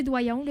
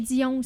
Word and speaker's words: Doyon: C'est déjà arrivé Doyon, le Dion aussi.